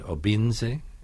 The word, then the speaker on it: Obinze: This novel follows Ifemelu and Obinze. Am I pronouncing it right? – Yes, I'm very impressed.